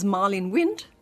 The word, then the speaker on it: Marlene Wind: My name is Marlene Wind.